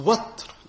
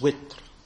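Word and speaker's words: وتر: So watr, which can also be pronounced witr.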